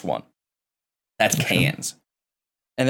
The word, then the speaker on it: Cairns: Uh, the cranes ones, the C A I R N S is cans.